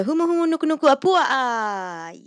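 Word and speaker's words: Humu Humu Nuku Nuku Apua'a (humuhumunukunukuāpuaʻa): The answer is the state fish of Hawaii is the Humu Humu Nuku Nuku Apua'a.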